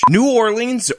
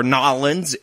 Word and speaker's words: New Orleans: New Orleans, or Nolens, if you're a local and you like the local pronunciation, is one of America's great cities.